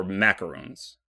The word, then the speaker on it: macaron: I need, let's see, it's going to be a cupcake, brownies, ice cream, or macarons. Am I saying that right? Macaron is the cookie.